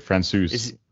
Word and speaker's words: Francouz: Is that how you say it? Francois? Francouz? – He's still injured. Francouz.